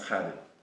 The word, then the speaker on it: Kadim: My name's Arabic, so it's Kadim. It's actually pronounced Kadim.